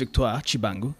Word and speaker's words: Victoire Tshibangu: First of all, my name is Victoire Tshibangu.